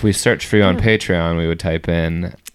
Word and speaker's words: Patreon: If you say Patreon, it's Patreon.